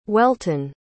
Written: welton

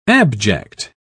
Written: abject

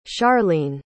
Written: sharlene

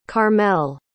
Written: carmelle

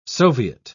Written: soviet

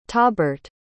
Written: tahbert

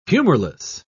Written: humorless

